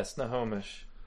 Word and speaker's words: Snohomish: Location is an address in Snohomish. – Snohomish? – Yeah, Snohomish.